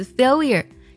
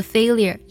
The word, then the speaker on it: failure: 这个单词很有意思， failure。英式的发音经常读作 failure。很多人呢读作 failure 也是正确的。